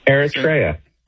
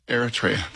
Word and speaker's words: Eritrea: Eritrea. – Eritrea.